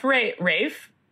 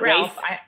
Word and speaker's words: Ralph Fiennes: Pronounced Rafe, not Ralph. I had a fight with a fist fight with someone the other day about this at work. They said it's Ralph Fiennes. And I said, no, fuck yourself. It's not Ralph.